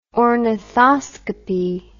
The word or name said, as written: ornithoscopy